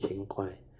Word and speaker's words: Qin Gui: Qin Gui, Qin Gui. Qin Gui, sorry, pronunciation not accurate, Qin Gui.